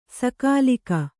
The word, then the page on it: ಸಕಾಲಿಕ: sakālika